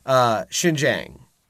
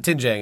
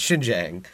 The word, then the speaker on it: Xinjiang: So the first one is it's pronounced Xinjiang. It's not Tinjiang, it's Xinjiang.